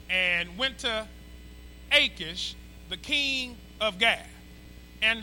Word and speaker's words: Achish: Okay, that's how you pronounce it, Achish.